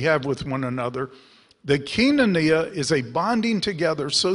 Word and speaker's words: koinonia: In the south, that's pronounced koinonia. But it, in Greece, it's koinonia, which is the primary word translated church.